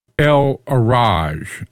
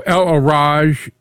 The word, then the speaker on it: El Araj: El Araj, A-R-A-J, and people pronounce the J either a little harder or a little softer. I'm going to pronounce it a little harder just so that people can hear the articulation.